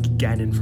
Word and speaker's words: Ganon: It's like Ganon from Zelda, if anyone's wondering how to pronounce it.